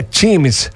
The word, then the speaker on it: Teams: Bom dia, sensacional o show ontem da DJ Teams, se pronuncia Teams, a DJ, ela esteve com a gente ontem, norte-americana, enfim.